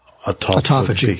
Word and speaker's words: autophagy: it downregulates the autog, autophagy, autophagy. – Autophagy. There we go.